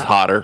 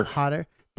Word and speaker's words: Hodder: To Hell and Back, the Keen Hodder story, or Hodder, I can never pronounce it. – It's Hodder. – Hodder.